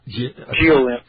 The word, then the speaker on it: Giolinn: And there was a time on target fired at, how do you say, is it Jiolin, G-I-O-L-I-N-N, sir? How do you say that? – Jiolin.